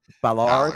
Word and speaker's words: Ballard: how do you say it? – Ballard, Ballard, Ballard.